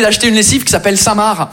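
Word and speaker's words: Saint Marc: On est d'accord les Français que Samar, ça s'écrit M-A-R-C, comme le prénom Marc. Mais vous, vous mettez un sein devant le prénom Marc. Le C, il est parti en grève.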